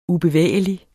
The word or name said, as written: ubevægelig